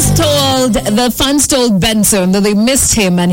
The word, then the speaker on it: BNXN: But when you are saying it, when you're pronouncing it, you don't say BNXN. No, you say Benson.